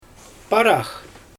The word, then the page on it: parax: Xyzyl